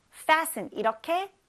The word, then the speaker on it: fasten: fasten이 아니고 fasten. fasten 이렇게 발음하시면 됩니다.